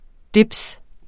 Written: dibs

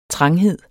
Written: tranghed

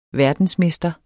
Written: verdensmester

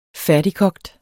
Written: færdigkogt

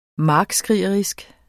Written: markskrigerisk

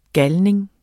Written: GALNINGENE

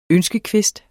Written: ønskekvist

